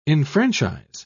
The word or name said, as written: enfranchise